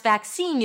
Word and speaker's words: vaccine: To pronounce vaccine, you start with a V sound, then the as in cat, vac, vac, a k sound, and then seen as, I've seen you before, seen, a high E, vaccine.